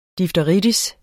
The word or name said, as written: difteri